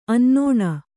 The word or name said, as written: ಅನ್ನೋಣ